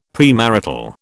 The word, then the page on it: premarital: British English